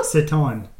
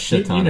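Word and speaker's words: Šatan: That is Mirslava Satan. – Miroslav. – Yeah, it's pronounced Shaitan, I think.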